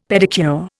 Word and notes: pedicure: Indian

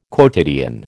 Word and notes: quotidian: American English

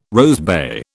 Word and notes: rosebay: British English

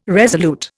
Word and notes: resolute: Indian

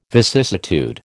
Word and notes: vicissitude: American English

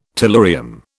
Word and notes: tellurium: British English

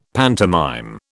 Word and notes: pantomime: British English

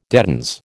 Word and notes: dedans: American English

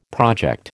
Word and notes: project: American English

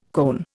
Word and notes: koan: Indian

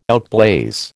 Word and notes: outblaze: American English

